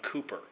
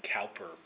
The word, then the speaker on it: Cowper: And then the other thing that I unaccountably left out was Cooper, spelt with a W. You might think Cowper, but you would be wrong.